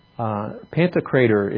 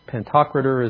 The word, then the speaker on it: Pantocrator: Pantocrator is the word in the Greek. Sometimes pentocrator is the way it's pronounced by some.